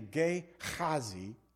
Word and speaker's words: Gehazi: It's actually Gehazi. That's how you say it, really.